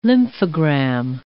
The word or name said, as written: lymphogram